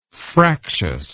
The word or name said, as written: fractiousness